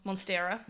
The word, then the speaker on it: Monstera: Monstera. – Monstera.